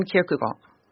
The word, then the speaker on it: Kierkegaard: C'est un Kierkegaard.